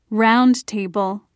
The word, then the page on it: roundtable: British English